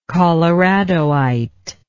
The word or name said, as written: coloradoite